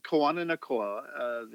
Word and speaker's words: Kawananakoa: Kawananakoa, the Hawaiian alphabet doesn't have as many letters as the English, but the pronunciation is pretty simple. It's somewhat similar to Spanish. So Kawananakoa, that's exactly how it's pronounced.